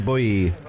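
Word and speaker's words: Boi: Arnie's and, pronounced boy.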